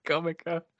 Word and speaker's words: Necronomicon: Did you just call it a Necrocomicon? – I can't pronounce it. – That is a coin that you should, sorry, that is a term that you should coin. That should be the name of the Evil Dead Comic-Con when it happens. – That would be awesome. Yeah, I know. That's what I always call it because I can never pronounce it right. – Necronomicon. Necronomicon.